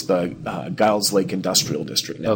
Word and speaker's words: Guild's Lake: But everyone pronounces this as Guild's Lake, and it kind of looks like Guild because the word is G-U-I-L-D. – Okay. – So it looks like Guild, but it is named after Peter and Elizabeth Guild, who were the original settlers there.